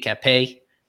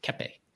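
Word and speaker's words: Cappe: Yidi Capé, who he likes to call Cap for some reason. Pronounce the E at the end, Capé.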